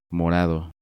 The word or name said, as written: Morado